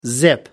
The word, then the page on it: zip: American English